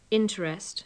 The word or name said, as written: interest